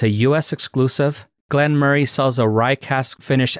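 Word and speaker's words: Glenmoray: It's actually Glenmurray 100 proof rye cask finish. And Murray, it's spelled M-O-R-A-Y. You kind of want to say Murray, but everything that I've seen, they always call it Glenmurray.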